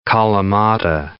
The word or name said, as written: kalamata